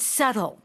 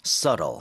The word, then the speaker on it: subtle: Subtly 아니라 발음이 어떻게 되죠? – Oh, that's right. The B is almost you can't hear it. So it's subtle. – Subtle.